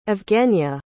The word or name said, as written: evgeniya